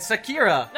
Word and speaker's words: Sakura: Yeah, he said it right. Sakura.